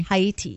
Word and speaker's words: Haiti: Haiti, yes.